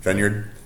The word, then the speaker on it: Feyenoord: Feyenoord.